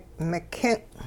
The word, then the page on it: mikin': Hupa